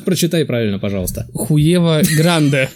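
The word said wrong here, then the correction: Huevo: В испанском не читается первая буква А. Уева? Да, это слово читается как Уэва.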